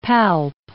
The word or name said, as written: palp